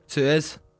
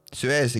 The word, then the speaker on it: Suez: How do you pronounce it? – Suez. – Suez, exactly.